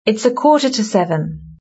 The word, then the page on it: it's a quarter to seven: British English